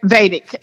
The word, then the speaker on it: Vedic: I get something, and I might be pronouncing this wrong, but is it Vedic or a Vedic? – Yeah, Vedic.